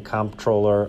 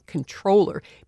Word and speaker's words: comptroller: My name is Keith Narica, and for most of 2017, I was the acting comptroller of the currency. – You're saying the P. – I'm saying the P and the M. – Narica says he never even considered saying controller because the word is spelled C-O-M-P-T-R-O-L-L-E-R.